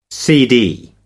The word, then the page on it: cd: American English